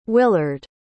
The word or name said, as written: willard